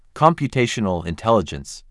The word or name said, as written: Computational intelligence